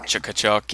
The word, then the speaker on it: Tkachuk: Tkachuk. – Kachuk? – Kachuk, yeah.